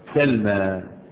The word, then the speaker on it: سلمى: المقصورة، ألف التأنيث المقصورة، تكتب ياء ولكن تنطق ألف. أنطقها ألف إزاي؟ لابد أن أفتح ما قبلها، لأن لو كسر ما قبلها بقت ياء، زي قاضي، قاضي قبلها إيه؟ مكسور، لكن لما أفتح ما قبلها تنطق ألفا. سلمى، سلمى، اسم عربي، سلمى.